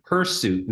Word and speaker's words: hirsute: And it's hirsute, not hirsute. – Okay, hirsute. Okay. Hirsute, yeah. I've been saying it wrong all these years. All right. – It's hirsute, yeah. – Hirsute. But H-I-R, not H-E-R. – That's exactly right. Sounds like hirsute.